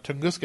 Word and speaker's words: Tunguska: Well, one of the questions that came up in the chat room from our friend Chops was about the Tunguska incident.